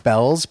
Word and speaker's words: Bealls: There's this place in Florida called Bell's, but it's spelled B-E-A-L-L-S.